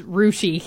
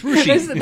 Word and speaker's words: Russia: It's spelled Russia. It's Rushi.